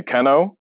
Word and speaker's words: Kenno: People call me Kenno. Kenno, like Keno, like the game. Nope, Kenno, two N's. Spelled like Keno with two N's.